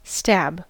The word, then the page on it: stab: American English